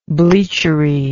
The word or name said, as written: bleachery